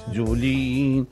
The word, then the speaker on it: Jolie: Jolie.